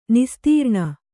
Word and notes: ನಿಸ್ತ್ರಾಣಿ: nistrāṇi

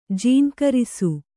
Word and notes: ಜೇಂಕರಿಸು: Kannada

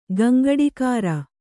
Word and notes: ಗಂಗಡಿಕಾರ: gaŋgaḍikāra

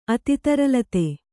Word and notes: ಅತಿತರಲತೆ: ati taralate

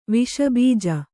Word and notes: ವಿಷಬೀಜ: viṣa bīja

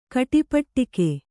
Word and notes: ಕಟಿಪಟ್ಟಿಕೆ: kaṭipaṭṭike

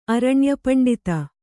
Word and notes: ಅರಣ್ಯಪಂಡಿತ: Kannada

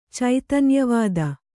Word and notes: ಚೈತನ್ಯವಾದ: caitanyavāda

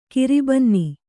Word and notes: ಕರ್ಬೊನ್ನು: karbonnu

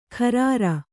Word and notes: ಖರಾರ: Kannada

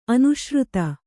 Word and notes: ಅನುಶ್ರುತ: anuśruta

